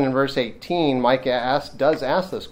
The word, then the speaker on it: Micah: Now, Micah is a name that's properly pronounced Mika.